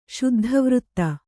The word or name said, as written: ಶುದ್ಧವೃತ್ತ